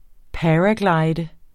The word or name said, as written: paraglider